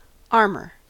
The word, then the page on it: armor: American English